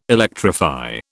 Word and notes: electrify: British English

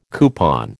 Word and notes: coupon: American English

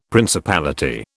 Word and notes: principality: British English

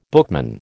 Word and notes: bookman: American English

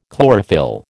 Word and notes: chlorophyll: American English

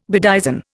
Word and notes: bedizen: Indian

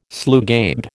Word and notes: slugabed: American English

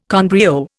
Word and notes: con brio: Indian

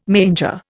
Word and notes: manger: Indian

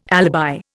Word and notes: alibi: Indian

